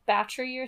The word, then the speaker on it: Bathory: Another proposed possibility is Elizabeth Bathory, whose name is actually pronounced, like, Battery or something like that.